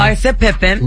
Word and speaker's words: Larsa Pippen: Larsa Pippen.